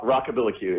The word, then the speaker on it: Rockabilly Q: Rock, rockabilly Q. It's like uh, rockabilly meets barbecue. Very southern.